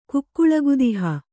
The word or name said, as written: ಕುಕ್ಕುಳಗುದಿಹ